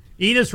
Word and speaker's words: Enes: Enes rhymes with penis.